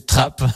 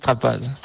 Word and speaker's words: Trappe: Donc voilà, comme vous l'avez rappelé, c'est chèvrerie de la Trappe ou de la Trappasse, ça dépend comment on le prononce. – Voilà, alors chez nous on disait Trappe, mais beaucoup de gens disent Trappasse.